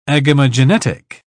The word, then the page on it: agamogenetic: British English